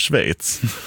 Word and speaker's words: Schweiz: Jag tänker att det kanske är lite samma sak med Schweiz.